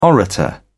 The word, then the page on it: orator: British English